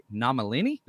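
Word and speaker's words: Nomellini: So Leo the Lion I can't say it right. Nomalini? There we go.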